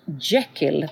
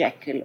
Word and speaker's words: Jekyll: Hon heter Gertrude Jekyll, tror jag man uttalar hennes namn, ungefär som Jekyll och Hyde, men Gertrude Jekyll.